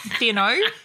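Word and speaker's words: Thiénot: Theonot. – Theonot. Theano.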